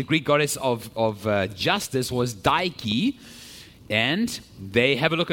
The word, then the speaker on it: Dike: The natives think that this is the work of one of their deities in the Greek pantheon called Dike. Dike. This is the Greek goddess of justice. It's pronounced just like Nike, which is the Greek goddess of victory.